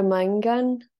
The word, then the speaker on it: Maingan: The name for wolf is Maingani, is that right? – Yeah, Maingan, yeah.